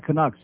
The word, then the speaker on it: Canuck: Panthers and a Canucks. Not Canucks, Canucks, 10 o'clock tonight. Canuck rhymes with schmuck.